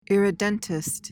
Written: irredentist

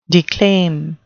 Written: declaim

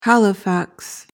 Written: halifax

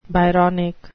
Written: byronic

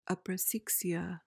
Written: aprosexia

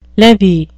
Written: levee

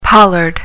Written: pollard

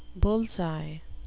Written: bulls-eye

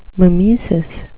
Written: mimesis

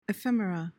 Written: ephemera

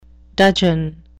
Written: dudgeon

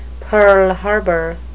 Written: pearl harbor

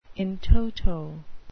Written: in toto

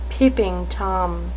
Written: peeping tom

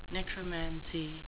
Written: necromancy

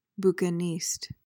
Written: bouquiniste

